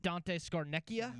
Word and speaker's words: Scarnecchia: Dante Scarnecchia? – Yeah, but he's not there anymore.